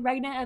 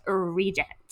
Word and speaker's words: regnant: regnant of England, and someone says it's pronounced regent, and I say no, those are two different words with two different definitions.